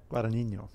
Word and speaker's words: Guadagnino: Luca Guadagnino. Is that... – Guadagnino.